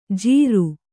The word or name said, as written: ಜೀರು